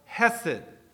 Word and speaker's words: hesed: So I'm going to pronounce it the English way, and I'm going to call it hesed. So this morning we're going to learn about the word hesed.